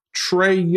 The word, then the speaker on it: Trae: Trae Young. Look at you, T R A E. You got the pronunciation right.